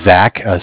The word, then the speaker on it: Zach: My name is Zach, spelled Z-A-C-H, and I'm from Bank of Cardiff, and Cardiff is spelled C-A-R, like car, D as in dog, I as in imagination, F-F like friend.